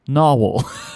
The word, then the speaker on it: Nahvalur: When I asked Frank, he said it's pronounced Narwhal. So basically, the brand's name went from Narwhal to Narvaler, but stayed Narwhal. So I suppose I'll stop using random pronunciations in videos. So the way you pronounce that word is Narwhal.